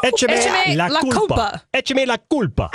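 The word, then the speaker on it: Échame la culpa: Well, you just said the accent in the wrong syllable.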